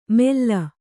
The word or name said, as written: ಮೆಲ್ಲ